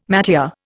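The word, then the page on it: métier: Indian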